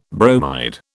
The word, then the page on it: bromide: British English